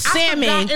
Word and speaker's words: salmon: Salmon. – It was even an L in salmon. – I'm y'all, like, and it's really, yes, there's an L, but that really, it really is silent.